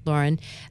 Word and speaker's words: Lauren: And Ralph Lauren. Am I saying that right? Mm-hmm. Or is it Lauren? – Ralph Lauren. – Lauren.